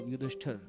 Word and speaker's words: युधिष्ठिर: युधिष्ठिर को जो युधिष्ठर